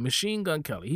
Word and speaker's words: Machine Gun Kelly: Why am I butchering this dude's name, Machine Gun Kelly.